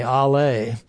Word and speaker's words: Allah: Foremost, of course, is Allah. And I was surprised when I looked in the dictionary that they have a long A, Allah.